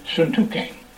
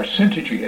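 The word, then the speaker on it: Syntyche: So he says, I want to say a word to Euodia and Syntuchane, or Syntuchi, as some have pronounced it, which is incorrect.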